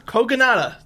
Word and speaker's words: Kogonada: How do you say mm-hmm. his name? – Koga. – Kogonada? – Kogonada. – Thank you. That's how I pronounce it.